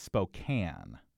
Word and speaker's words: Spokane: Most people have heard of this at least, but maybe aren't pronouncing it correctly. I think I know. It's spelled Spokane. I believe it's Spokane. – Yes. – Very good.